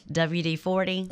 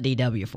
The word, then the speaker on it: WD-40: WD-40. – Oh, you got it right. – Okay, normally I call it DW-40.